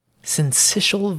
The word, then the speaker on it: syncytial: Yeah, respiratory syncytial virus. I looked up the pronunciation, and I thank goodness I remembered because when I look at that word, I don't see syncytial. But uh, the respiratory syncytial virus, other, otherwise known as RSV, it's finding a foothold in the U.S.